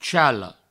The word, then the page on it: chale: provençal